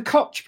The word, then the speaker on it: Koch: So they are the Koch brothers. – They are. Then again, I'm going to have another question about how you pronounce that. Is it the Koch brothers? – Definitely Koch brothers.